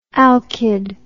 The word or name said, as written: alkied